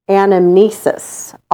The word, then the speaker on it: anamnesis: So this sounds kind of similar, but it's anamnesis. All true knowledge is anamnesis, which is the act of remembering what the soul already knows.